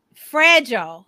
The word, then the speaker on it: fragile: Fragile. That's not fragile. That's a long I.